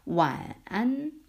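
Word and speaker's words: 완안 (晚安): 하나하나 천천히 끊으면. 삼성의 완두콩 할 때 완자예요. 근데 이제 성조가 들어가니까 발음이 좀 뭐지? 이렇게 들을 수 있어요. 완안, 완, 완, 완두콩 완, 완안. 그 다음에 안, 우리 안녕할 때 안이에요. 성조를 넣어야 돼. 안.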